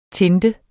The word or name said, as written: TINTET